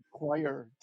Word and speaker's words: coir: I've heard choir too.